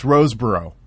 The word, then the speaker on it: Rosebrough: Is it Rosebrough? – It's Rosebrough. That's okay. How they pronounced it. I just listened to another radio show that he was on, and that's how they pronounce it.